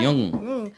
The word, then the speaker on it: Jung: Jung.